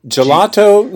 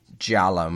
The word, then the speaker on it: Giallo: Gelato? No. – No. It's spelled G-I-A-L-L-O. Giallo. And it's the Italian word for yellow.